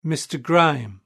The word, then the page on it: mr grime: British English